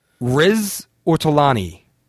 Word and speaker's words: Riz Ortolani: Help me out here, Joe. Riz Ortolani. – Yes.